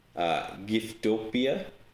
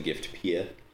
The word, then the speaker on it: Giftopia: And then probably if in Japanese, it would be like Giftopia. So it's probably more like Giftopia. So we probably call it Giftopia. It makes more sense than Gift Pia.